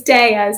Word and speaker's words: Dea: It's Dea, so it's Italian, it means goddess.